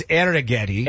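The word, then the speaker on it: Arrighetti: No, you laughed at me the same way I said Andrew Geddy is going to pitch a gem tonight. – Well, you could say his name. It's Arrigetti.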